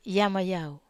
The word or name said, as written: yama yau